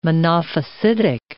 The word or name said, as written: Monophysitic